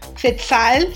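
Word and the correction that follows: Tzeltal: Ni siquiera sabes pronunciarlo, güey. – Zeltal.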